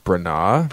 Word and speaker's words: Branagh: I believe it's pronounced Kenneth Branagh.